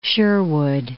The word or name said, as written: Sherwood Forest